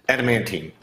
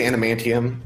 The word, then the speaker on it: adamantine: Adamantine? – Adamantine. Okay, yes. I always say adamantium, and that's not what it is.